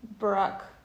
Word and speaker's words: Barack: Michelle oder mit Barack. – Wie spricht man das aus? – Barack.